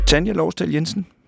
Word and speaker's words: Tanja Lårsdal Jensen: Tanja Lårsdal Jensen, er det rigtigt udtalt? – Det er det faktisk, Peter.